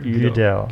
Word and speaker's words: Gödel: It's Gödel. So I went on Wikipedia and I clicked listen and it said Gödel. Kurt Gödel.